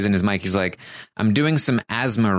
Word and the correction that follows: ASMR: Do you mean ASMR?"